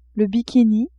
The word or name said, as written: bikini